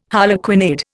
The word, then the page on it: harlequinade: Indian